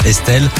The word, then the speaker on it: ESTL: Elle a enlevé quelques voyelles pour choisir son nom d'artiste, Estelle, mais ça s'écrit E-S-T-L.